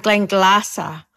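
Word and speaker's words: Glenglassaugh: Benriach in Speyside, Glendronach in the Eastern Highlands, and Glenglassa on the coast.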